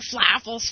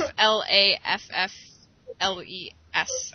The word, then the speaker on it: Flaffles: It's F L A F F L E S. I can't say words. Yeah. Flaffles. – what? Flaffles. Yeah, that's what I said.